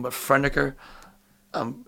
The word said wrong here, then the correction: Franeker: Franeker, yeah.